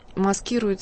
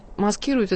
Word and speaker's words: маскирует: Он находился в настоящей клинической депрессии, а клиническая депрессия часто маскирует себя алкоголизмом. Маскирует это, кстати, медицинское ударение. По-русски мы бы сказали «маскирует».